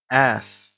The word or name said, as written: ass